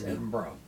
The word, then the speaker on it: Edinburgh: Well, Edinburgh, Scotland, which pronounced Edinburgh, by yes. – the way, by the locals.